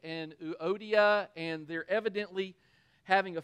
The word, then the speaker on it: Euodia: I plead with Euodia and I plead with Syntyche. That's how you say it.